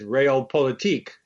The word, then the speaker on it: Realpolitik: Okay, to another German word you discuss in your book is Realpolitik. Am I pronouncing that correctly? – That's how I pronounce it.